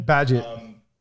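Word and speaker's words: Bagehot: I mean, so when he was talking about Walter Bagshot, I believe is the pronunciation. – Badgett. – Badgett. Okay, right. Badgett.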